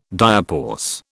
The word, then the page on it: diapause: British English